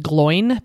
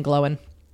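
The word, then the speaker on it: Glóin: Some people say Oin and Gloin because that's how it's spelled, but there's an accent there. It's Owen and Glowin.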